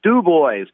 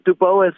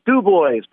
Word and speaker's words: Du Bois: And yeah, we're ready to come to Du Bois, not Du Bois or Du Bois. It's Du Bois, Pennsylvania.